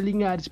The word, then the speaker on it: Linares: Ele é cantor e compositor, tem uma filhinha linda e tá aí fazendo a música dele repetindo, arroba Léo Linares, se pronuncia Linhares, porque tem ali um tiozinho meio, meio espanhol, meio latino, underline LL no Instagram.